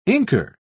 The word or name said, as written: inker